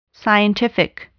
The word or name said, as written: scientific